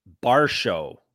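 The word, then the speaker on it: Borshchev: they were saying Vyacheslav Barshow, like B-A-R-S-H-O-W. – Like Dalton Varshow. Like Dalton Varshow. – Yeah. Like, yes. Barshow, like Darshow.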